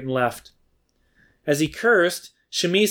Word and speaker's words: Shimei: I had to ask a friend of mine who knows Hebrew really well how to pronounce his name, because I didn't know if it was Shimei or Shimi, and she tells me it's Shimi, so I'm going to try to pronounce it right.